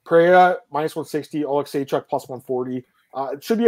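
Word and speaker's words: Oleksiejczuk: Middleweight division, we have Michelle Pereira against Michael Oleksychuk. I said his name right this time, finally, Marcel.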